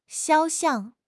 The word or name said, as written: 肖像